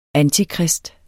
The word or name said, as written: antikrist